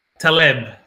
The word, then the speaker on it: Taleb: Taleb.